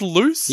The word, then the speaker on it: Luce: And before you do your little sneaker, it's L-U-C-E. – Home movie, is it? – L-U-C-E, you sick bastard. – Is it pronounced Loose? – Yes, it is. I've seen the movie. – You sure it's not Luce?